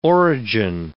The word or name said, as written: origin